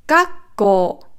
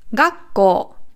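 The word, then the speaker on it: Gakko (学校): Also hier ist die Betonung auf dem Ga und auf dem Ko. Gakko. Nicht Gakko. Also nicht hoch, sondern runter. Da, da. Gakko. Hoch, runter. Gakko. Und lang natürlich.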